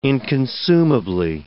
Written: inconsumably

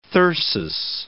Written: thyrsus

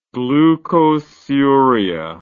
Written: glucosuric

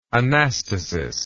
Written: anastasis

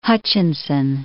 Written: Hutchinson

